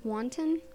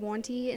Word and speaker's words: wanton: The word wanton, you said wanty instead.